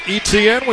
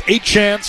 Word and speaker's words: Etienne: Last year it was Etienne. We found out from brother Travis. The last name is now pronounced Achan, so it's Achan.